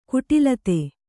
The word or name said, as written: ಕುಟಿಲತೆ